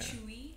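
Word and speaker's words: CHUI: That's why I wrote it, like, the way it's pronounced. – Yeah. – Chewy.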